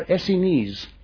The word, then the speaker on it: Essenes: Incidentally, is that the correct pronunciation? Essenes or Essenes? It is Essenes.